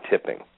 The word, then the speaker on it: Tipping: His name is Colin Tipping. Last name is spelled T-I-P-P-I-N-G, like you would a waiter, Tipping. – Okay, I got it. Yes, awesome. – First name, Colin, C-O-L-L-I-N.